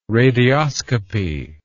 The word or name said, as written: radioscopy